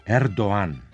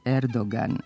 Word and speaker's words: Erdoğan: Ali u posljednje vreme, na konferencijama za medije, naši najviši politički zvaničnici zovu ga Erdoğan, za čim su se poveli i mnogi izveštači i mediji. Dakle, je li turski predsjednik Erdoğan ili Erdoğan? – Turski predsjednik je Erdoğan, a ne Erdoğan.